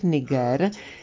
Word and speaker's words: Niger: je správná výslovnost s přízvukem na první slabice Niger.